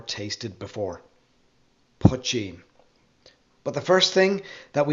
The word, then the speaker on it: poitín: Break it into its two syllables. The first is poch, like much, and the second is een, like bean. When you put them together, you get poitín.